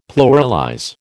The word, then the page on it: pluralize: American English